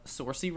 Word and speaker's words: Saoirse: I had to look up how to pronounce her name. It's Saoirse. – Saoirse? – Yeah. – Oh. – I always called her Saoirse. – I even thought I was saying it right. – Saoirse Ronan. No, apparently it's Saoirse. I don't know what nationality that is, but... – She's Scottish, I think. – Scottish. Saoirse. It's like Sasha, but drunk.